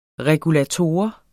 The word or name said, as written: regulator